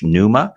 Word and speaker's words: Neuma: He is the global senior pastor of Nuba Church, Numa, that's spelled N-E-U-M-A, after, of course, the Greek word pneuma, meaning spirit, Holy Spirit.